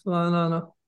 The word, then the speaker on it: Ra'anana: But I want to bring on someone who is from, I'm going to make sure I pronounce it right, Ra'anana? – Yes, Ra'anana.